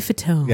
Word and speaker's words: Fatone: Joey Fatone.